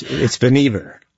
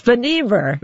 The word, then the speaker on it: Vannevar: It's Vannevar. – It's Vannevar. – But everybody called him Van. I guess that made it a lot easier. I was putting the accent on the wrong syllable there.